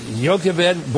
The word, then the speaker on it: Jochebed: It's spelled J-O-C-H-E-B-E-D.